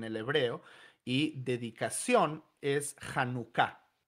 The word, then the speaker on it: Hanukkah: Sí, entonces en el hebreo esa palabra tiene el énfasis en la última sílaba, lo que llamaríamos una palabra aguda en el español, Hanukkah.